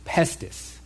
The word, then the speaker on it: pistis: Now, the Greek word used here is spelled P-I-S-T-I-S, and that's pronounced pestis.